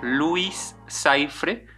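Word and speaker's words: Louis Cyphre: se le conoce como Luis Saifre, que este nombre eh, pues es un juego de palabras que se pronuncia prácticamente como Lucifer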